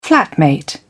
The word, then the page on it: flatmate: British English